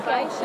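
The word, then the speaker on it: Baixas: It's actually pronounced Rias, like real almost. Rias and then Vaixas, okay. But the spelling kind of throws you off. And V-A-I-X-A-S is how you spell the name Vaixas. So what it translates is to kind of river inlets, or Rias, and Vaixas is low.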